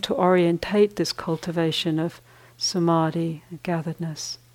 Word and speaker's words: samadhi: The D-H-I at the end of the word has the sense of holding. And the middle part of the word, the long A, samadhi, has the sense of a movement in it,